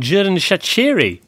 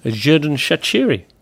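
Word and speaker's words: Xherdan Shaqiri: How about we do a little bit on Jürgen Schaciri? Because that's how you pronounce it, I've now discovered. Jürgen Schaciri.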